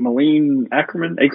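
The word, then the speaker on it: Akerman: I thought the best performance here is, I think, Malin Ackerman. That's how you say it.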